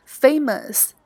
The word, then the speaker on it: famous: 第三个 amazing 令人吃惊的，第四个 famous 著名的，在这里要特别强调它的读音 famous 没有儿化音哦。